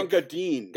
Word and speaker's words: Gunga Din: Gunga Din. That's how you pronounce it. – Right. – I read shit after. – And then I suppose in the movie they say Dean, so whatever.